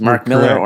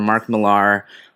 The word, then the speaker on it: Millar: we like Googled this, and both pronunciations, Mark Miller or Mark Millar, are both correct, I think.